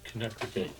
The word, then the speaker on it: Connecticut: who's listening in Connecticut. That's how you say it, right? – Sam? – No, Connecticut. – Connecticut, yeah.